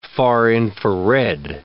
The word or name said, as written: far-infrared